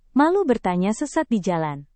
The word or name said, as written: malu bertanya sesat di jalan